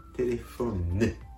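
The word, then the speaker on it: téléphone: テレフォンね。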